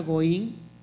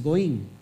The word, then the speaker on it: Goyim: La palabra Goín se escribe como Goyim, es Goín.